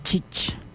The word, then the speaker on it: chich: En lengua yocotán, tía se escribe chich y se pronuncia chich.